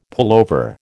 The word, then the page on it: pullover: American English